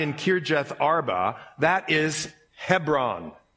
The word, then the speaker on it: Hebron: Everybody say Hebron. We grew up in a town, and the next town over was Hebron. We called it Hebron. I didn't know I'd been pronouncing it wrong for a long time, but whatever.